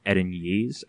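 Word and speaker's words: Erinyes: Their actual name, they all share the same name, is they're all Edenyees, and it's just one singular Edenyee.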